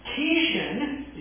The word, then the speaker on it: Kishon: Kishon, is the way I believe the Jews pronounce it.